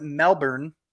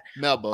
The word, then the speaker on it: Melbourne: That's not how you say it. How the fuck do you say that? – Melbourne. – Aussies, please. – Melbourne. – Melbourne. Melbourne. – Melbourne. Melbourne. – Is that real? North Melbourne.